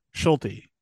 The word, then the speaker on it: Schulte: Schulte. – Yeah, you pronounce it. Yeah, you pronounce the T.